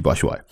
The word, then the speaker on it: Batshuayi: You know, Euro 2016, UEFA did this thing about how to pronounce player names, and it was Batshuayi, and I went, okay, this is a good football player for Marseille.